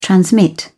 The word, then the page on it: transmit: British English